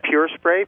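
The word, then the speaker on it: PUR Spray: And we pronounce that Pure Spray, P-U-R Spray? – That's correct. Thank you.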